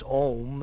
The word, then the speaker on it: Om: And the first one is Om, and it's pronounced as you would pronounce Hom, except leave the H off.